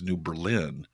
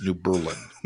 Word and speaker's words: New Berlin: Or my favorite, when I moved to Milwaukee, Wisconsin, and kept pronouncing the suburb New Berlin as New Berlin. But the third time I did it, somebody finally corrected me and said, not since World War II, it's New Berlin.